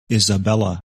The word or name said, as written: Isabella